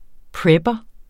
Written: prepper

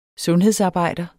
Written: sundhedsarbejder

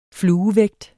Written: fluevægt

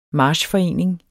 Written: marchforening

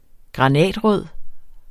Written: granatrød

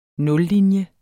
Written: nullinje

